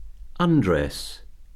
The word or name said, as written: undress